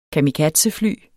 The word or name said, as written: kamikazefly